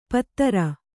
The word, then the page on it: ಪತ್ರ: Kannada